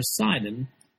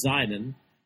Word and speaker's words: Sidon: Well, we see in this passage, just these four verses, that the prophet turns to Tyre's trading partner, Sidon, sometimes pronounced Zidon, which was just 20 miles up the coast.